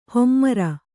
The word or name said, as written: ಹೆಮ್ಮರ